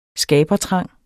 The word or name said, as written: skabertrang